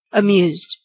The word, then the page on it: amused: American English